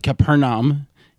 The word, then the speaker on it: Capharnaum: And that's because we'll be talking about Nadine Labaki's Capharnaum. Is that how you pronounce it? – That sounds good. They pronounce it Capharnaum.